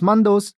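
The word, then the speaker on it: Mandous: Pronounced as mandos,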